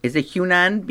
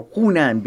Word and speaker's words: Hunan: Jim finally decided after much consternation to order the Hunan beef or Hunan beef or... How do you say it? – Either one.